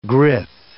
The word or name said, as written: grith